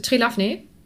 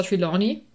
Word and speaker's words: Trelawney: Ja, also das Kind oder die kindliche, die sagt Trelafne. Und ich muss gestehen, ich weiß, wie es richtig ausgesprochen wird. Ähm, also es ist Professor Trelawney oder Trelawney, weiß, in meinem Kopf, wenn ich es lese, dann lese ich das so, wie ich das als Kind gelesen habe.